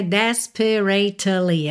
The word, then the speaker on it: desperately: Desperately. Aqui você não pronuncia dois S, tá? Não é desperately, é desperately. Desperately. Pensa P-R-A-T-L-Y, tá?